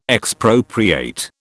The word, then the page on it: Expropriate: British English